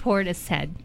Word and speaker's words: Portishead: Portis head.